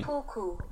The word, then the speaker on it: seppuku: Seppuku or seppuku, Japanese. Seppuku or seppuku, Japanese. – I like how it clarifies Japanese. Japanese. – Japanese. So I guess there's two different pronunciations, so everyone was right.